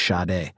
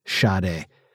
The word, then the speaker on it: Sade: I think it's like Sade, but you do it with like you're like not paying attention to the fact that you're pronouncing a sh at the beginning. It's like Sade. It's a quick sh.